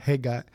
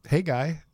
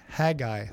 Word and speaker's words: Haggai: Hey guy. And so I said, no, I think it's, you know, pronounced hey guy. Or she thought it might be like Haggai, like a hag. – Yeah. – I-E-Y-E, Haggai.